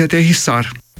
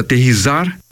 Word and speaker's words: aterrissar: Uma delas é aterrissar, que é pronunciada aterrizar e escrita aterrizar com Z.